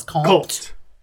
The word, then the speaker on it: compte: Compte.